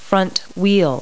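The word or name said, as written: front wheel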